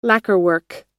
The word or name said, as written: lacquerwork